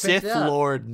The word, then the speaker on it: Sith Lord: What a shit lord compared to Rey. – You huh? – know what? – It's pronounced Sith Lord, Nick.